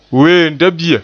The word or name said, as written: weemdebia